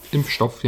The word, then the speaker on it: Impfstoff: Vaccine? Impfstoff? – Impfstoff, yeah. – Ha! It's the first time I pronunciate this word well.